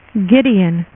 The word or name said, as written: gideon